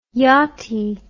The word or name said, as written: yottie